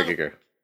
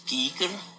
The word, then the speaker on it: Giger: Geiger. Giger. – My name is Giger.